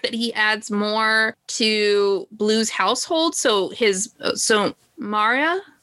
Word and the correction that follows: Maura: I'm not sure how her name is spelled. M-A-U-R-A. – Maura. – Okay. – Yeah, I always say Maura in my Maura.